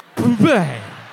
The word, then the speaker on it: Bublé: I need everyone to listen to the pronunciation. Paul, roll it. – Ladies and gentlemen, Michael Bublé. – Bublé. – He hits boob so hard.